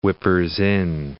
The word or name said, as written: whippers-in